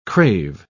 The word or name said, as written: crave